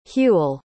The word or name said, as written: hewel